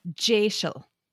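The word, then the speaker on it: deiseal: But it is an anglicization of a Scottish Gaelic word, and the word in Scottish Gaelic I think is D-E-S-I-L, but it's pronounced Jaishel.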